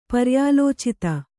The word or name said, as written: ಪರ್ಯಾಲೋಚಿತ